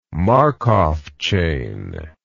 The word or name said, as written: Markov chain